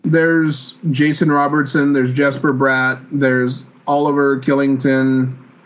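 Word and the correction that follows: Kylington: It starts with a K, but it's pronounced Shillington. It's very weird.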